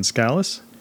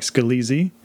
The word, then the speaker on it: Scalese: I've been very busy, so we're going to get straight to the interview with Diane Scalese. Her name looks like Scalese. It's pronounced Scalese. Having trouble? Just remember it rhymes with Alice.